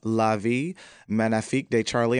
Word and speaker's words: La Vie Magnifique de Charlie: The Bronze Lens Film Festival will be screening your film, La Vie Magnifique de Charlie. Am I saying that right? – You have it 100% correct.